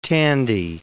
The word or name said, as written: Tandy